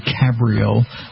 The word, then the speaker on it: Cabrio: I had the Fiat Abarth convertible, the Cabrio, proper way to say it.